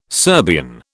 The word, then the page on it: Serbian: British English